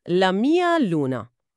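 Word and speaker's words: la mia luna: تلفظش هم هست لامیا لونا.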